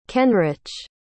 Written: kenrich

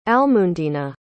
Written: almundena

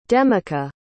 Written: demica

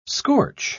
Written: scorch

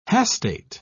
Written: hastate

